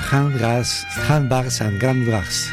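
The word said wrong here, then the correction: Handbags and Gladrags: Difícil de pronunciar. Ahora lo intento otra vez. Handbags and Glad Drags. Correcto.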